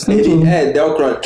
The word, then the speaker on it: Edelkrone: Acho que é Edelkrone, é eu não sei pronunciar o nome dessa. – é Edelkrone. É, essa aí mesmo.